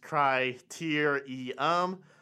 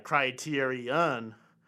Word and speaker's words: criterium: And yes, that's criterium, pronounced cry-tier-e-um, not cry-tier-e-un.